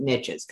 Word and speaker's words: niches: That's why I pronounce it niches, because it rhymes with riches.